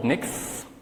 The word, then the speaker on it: Nix: It's called Nix. Pronounce it like you're a snake.